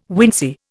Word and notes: wincey: Indian

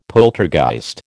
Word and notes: poltergeist: American English

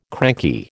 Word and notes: cranky: American English

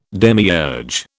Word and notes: demiurge: British English

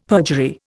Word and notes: perjury: Indian

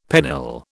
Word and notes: pennill: British English

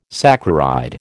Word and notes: saccharide: American English

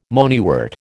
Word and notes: moneywort: American English

